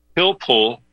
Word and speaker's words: Pilpul: Uh, I recommend uh, the listeners check out something called Pilpul for Beginners.